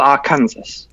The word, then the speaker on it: Arkansas: Is Arkansas the one that's spelled Arkansas? – Yes, Arkansas. Yeah, that has confused me until I was about far.